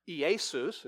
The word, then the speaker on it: Iesus: In fact, the name Jesus, which is the Greek Iesus, is how you say it.